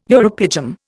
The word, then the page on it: uropygium: Indian